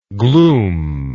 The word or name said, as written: glumelike